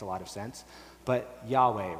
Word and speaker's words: Yahweh: So there's actually four Hebrew consonants there, YHWH, which scholars generally agree now is supposed to be pronounced Yahweh, okay? But it seems like they don't actually know for sure,